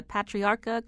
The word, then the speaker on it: Patriarca: Starting with, if I say this correctly, the Patriarca crime family. – You nailed it.